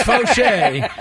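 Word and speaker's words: Faucher: You got to say it right. – So Fauché. – Okay, Fauché. – He was the guy. – It's Fauché.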